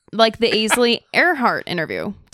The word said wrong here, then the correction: Ainsley: Did I say her name right? – Ainsley.